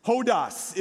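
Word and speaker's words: Hodas (ὁδός): Hodas is how you say that in the Greek. Everybody say hodas.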